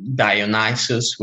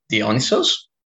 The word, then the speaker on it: Dionysos: And so a lot of people might not really recognize names immediately, because we don't say, for example, Dionysus, we say Dionysos.